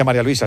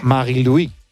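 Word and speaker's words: Marie-Louise: No se dice María Luisa, se dice en su... Marie-Louis. Marie-Louis Eta.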